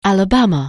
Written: Alabama

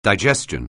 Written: digestion

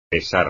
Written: pesar

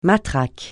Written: matraque

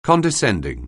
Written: condescending

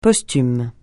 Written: posthume

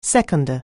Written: seconder

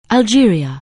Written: Algeria

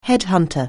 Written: headhunter

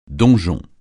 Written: donjon

